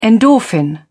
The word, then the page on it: endorphin: British English